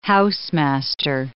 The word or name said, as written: housemaster